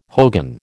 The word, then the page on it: hogan: American English